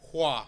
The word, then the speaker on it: Hua: Hua. – Mulan. – It's similar to Fa, but it's Hua.